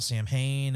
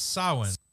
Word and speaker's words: Samhain: Like the whole scene where they go to the kindergarten or the preschool or whatever, like, and they talk about Samhain and all that and try and start to... not how it's pronounced, by the way. – It's Samhain.